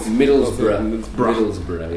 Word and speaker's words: Middlesbrough: Borough, how it is called? – Middlesbrough. Yeah. – I'm sorry, I don't pronounce this correctly.